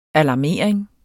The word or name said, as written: alarmering